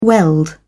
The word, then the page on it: weld: British English